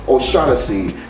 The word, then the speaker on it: O'Shaughnessy: And we got another guy, tight end, O'Shaughnessy. It rhymes with Hennessy, okay?